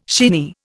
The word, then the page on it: shinny: Indian